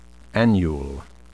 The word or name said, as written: annule